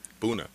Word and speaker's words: Buna: B-U-N-A. – Okay. – Complex. – Oh, Buna. – Buna. – Oh, it's pronounced Buna. – B-U-N-A.